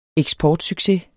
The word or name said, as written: eksportsucces